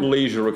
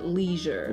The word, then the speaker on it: leisure: Да, или а, американцы скажут даже leisure. – Leisure. – Да, пишется да. – как L-E-I-S-U-R-E и... – Вот в конце sure, который вы знаете, как быть уверенным в чем-то.